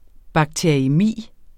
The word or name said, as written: bakteriæmi